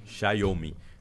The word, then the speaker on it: Xiaomi: Xiaomi. Né? Porque o, o, é como se fosse um tchau em italiano.